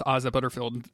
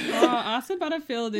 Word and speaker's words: Asa Butterfield: I think you did it wrong just then, but we love it. – Okay, cool. Awesome. – Asa Butterfield is like the guy that I think looks like Ilsa.